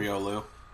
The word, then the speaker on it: Riolu: It's pre-evolution Rylaro or whatever the hell it's called. – Riolu, whatever.